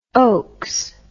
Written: oaks

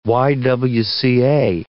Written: YWCA

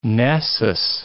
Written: Nessus

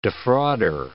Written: defrauder

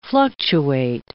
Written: fluctuate

